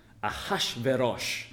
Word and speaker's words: Ahasuerus: Ahasuerus, originally pronounced in the Hebrew, would sound something like Ahashverosh.